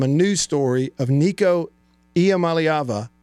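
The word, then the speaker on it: Iamaleava: Okay, the correct pronunciation, easy for me to say, the correct pronunciation, save that one for the soundboard, is Ia Malayava. Did I get it? Ia Malayava. Ia Malayava.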